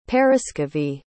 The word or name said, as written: paraskevi